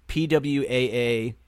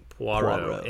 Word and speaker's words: Poirot: Yeah, Ross Perot. – Poirot. – PWAA-ROW. – Poirot.